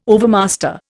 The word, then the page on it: overmaster: Indian